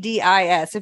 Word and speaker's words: Charybdis: Charybdis? It's Charybdis.